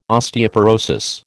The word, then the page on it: Osteoporosis: American English